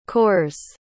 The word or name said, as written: cores